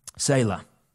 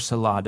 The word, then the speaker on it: Selah: And then notice there, off to the side there, you're going to see that word, Selah. Or Selah, depending on how you want to pronounce it.